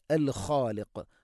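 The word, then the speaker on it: അൽ ഖാലിഖ് (الخالق): ഹുവല്ലാഹ് ഹുവല്ലാഹ് എന്ന് പറയരുത്, ഹുവല്ലാഹുൽ ഖാലിഖ്, അൽ ഖാലിഖ്. ഖ തൊണ്ടയുടെ മുകൾ ഭാഗം, ഖാഫ് അതിൻ്റെയും മേലെ ചെറുനാക്കിൻ്റെ അടുത്ത്, അതുകൊണ്ട് ശ്രദ്ധിക്കുക. അൽ ഖാലിഖ് രണ്ടും സ്വരഭാരത്തിൽ ഉച്ചരിക്കേണ്ട അക്ഷരമാണ്, ഖാലിഖ് എന്നല്ല. അൽ ഖാലിഖു ഹുവല്ലാഹുൽ ഖാലിഖുൽ ബാരിഉ, അൽ ബാരിഉ ഹംസ, അൽ ബാരിഉ ഹംസ കൃത്യമായിട്ട് ഉച്ചരിക്കണം.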